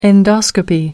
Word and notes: endoscopy: American English

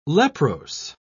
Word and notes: leprose: British English

